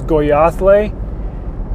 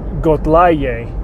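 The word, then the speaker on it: Goyaałé: I said it was Goyathle, but I was saying that wrong. It was Gotlaye. So I apparently had been not paying close enough attention to the placement of consonants on that one.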